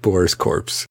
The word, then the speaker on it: Corpse: Join the corpse with Boris Corpse. – I get it. It's spelled like core.